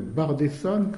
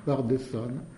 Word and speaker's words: Bardesan: On a donc aussi dans cette zone un écrivain qui s'appelle Bardesan, comme ça se prononce, qui est Bardesan, qui est le premier écrivain chrétien et qui aurait été lui-même disciple d'un gnostique.